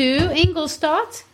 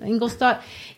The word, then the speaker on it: Ingolstadt: We'll talk about him a lot next week because there's things to say, but we need to rush off to Ingolstadt. Did I say that right? – You did. – Ingolstadt.